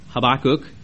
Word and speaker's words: Habakkuk: Another story that goes with this particular message is the book of Habakkuk, or Habakkuk, as Kirk Glebe more properly pronounced it.